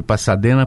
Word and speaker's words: Pasadena: Escreve Pasadena, a pronúncia é Pasadena.